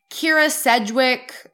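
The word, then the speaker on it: Kyra Sedgwick: Kira Sedgwick.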